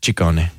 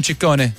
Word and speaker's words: Ciccone: Well, in Italian, it works the other way. So the double C's are hard C's and the singular C's are soft C's. – So Ciccione. – Ciccone.